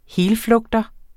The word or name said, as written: helflugter